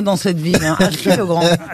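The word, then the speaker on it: Achiet-le-Grand: Pour Christine Domiti, qui habite à Chier-le-Grand. Dans le Pas-de-Calais, à Chier-le-Grand. Ça s'écrit à Chier, c'est à Chier-E-T.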